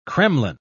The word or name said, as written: kremlin